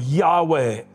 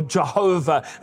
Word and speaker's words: Jehovah: As he takes a step of faith, the word Lord there is the covenant name of God, Yahweh, or Jehovah, as some pronounce it.